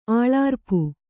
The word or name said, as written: ಅಳರ್ಪು